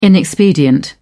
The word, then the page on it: inexpedient: British English